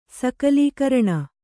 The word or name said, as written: ಸಕಲೀಕರಣ